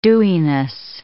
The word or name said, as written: dewiness